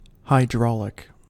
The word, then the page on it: hydraulic: American English